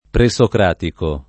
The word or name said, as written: presocratico